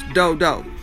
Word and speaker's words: Dodow: It's pronounced Do-Do, but it's spelled D-O, D-O-W.